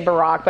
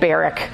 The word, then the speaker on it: Barak: And especially as we kind of go on to verse 6 now, when she's now going to send for the military leader, I always say Barack, but I think it's actually, because I think of Barack Obama when I see the name, but it's actually pronounced, I think, Barak in the Hebrew.